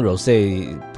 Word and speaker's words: Rosé: 我之前都念 Rosé 对不对，但是我发现好像 Rosé 才是正确的发音，没关系，知错能改，赶快调整。